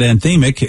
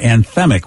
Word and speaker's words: anthemic: Vicky taught us that anthemic is actually pronounced that way and not anthemic, which I've been doing my entire life, and it's very pathetic that I did that.